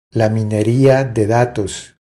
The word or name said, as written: La mineria de datos